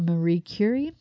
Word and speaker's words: Curie: I do have a quote too that I found on fear from Marie Curie.